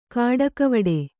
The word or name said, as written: ಕಾಡಿಗೆವಿಡಿ